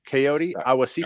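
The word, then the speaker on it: Kayode Awosika: Coyote Awosika, did I say that right? Do you know? Have any idea how to say that name correctly? Coyote Awosika. – Sounds good to me.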